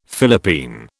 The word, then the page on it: Philippine: British English